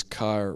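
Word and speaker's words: kara (χαρά): Chara. Chara, or as the pronunciation says, kara, or whatever. Yes, it's kara, kara, but with like a ha in there, or something like that.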